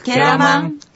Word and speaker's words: ケラマン: くんねいはあいぬいたくけらまん。 – このけらまんという単語ですけども、くえらまんというのが、続けて発音されて、けらまんというふうになります。